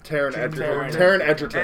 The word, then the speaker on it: Taron Egerton: Last night, holy crap, I got to see the new Robin Hood movie with Taron Egerton and, did I say that right? – Taron Egerton.